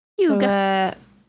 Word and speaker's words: hygge: Hygge. Like a hug.